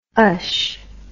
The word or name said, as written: ush